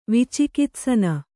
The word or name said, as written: ವಿಚಿಕಿತ್ಸನ